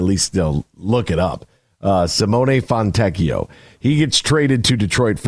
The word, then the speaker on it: Fontecchio: Then, yesterday, the big one is Simone Fontecchio. That's how you say it. Simone Fontecchio.